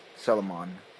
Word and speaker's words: Solomon: Salomon, right? – Are you talking about the Bible? – Yeah. – I don't know. – I think it was Solomon.